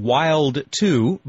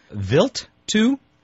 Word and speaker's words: Wild 2: we've been calling Wild 2, but to be more precise, it would be pronounced Vilt 2? – Yeah, it was discovered by Paul Vilt.